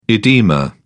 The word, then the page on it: oedema: British English